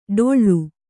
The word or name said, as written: ಡೊಳ್ಳು